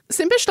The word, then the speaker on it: Simpich: Simpish dolls were a thing, and they were made in Colorado Springs. – How do you spell it? – S-I-M-P-I-C-H. – Oh, wow. – So again, confusing spelling.